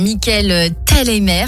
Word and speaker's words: Thalheimer: Le metteur en scène, Michael Thalheimer, c'est comme ça que ça se prononce, fait débuter le spectacle par la scène finale, celle du meurtre.